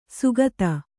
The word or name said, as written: ಸುಗತ